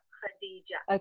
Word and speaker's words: Khadija: K-H, but it's pronounced Khadija.